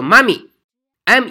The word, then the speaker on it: mummy: 木乃伊的单词叫做 mummy，m u m m y，mummy。注意这个发音不是你说的 daddy 妈咪，你的那个妈妈那个妈咪不是的，它是 mummy，mummy，m u m m y，就是木乃伊的意思。